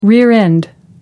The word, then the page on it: rear-end: American English